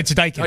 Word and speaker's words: Daikin: It's Daikin. – Daikin Park. – Daikin. – Daikin. – Looks like Daikin. Daikin, pronounced Daikin. Yes. Yep.